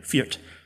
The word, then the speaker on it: feart: Feared. F-E-A-R-T. That's how you say it.